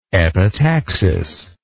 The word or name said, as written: epitaxic